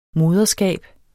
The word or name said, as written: moderskab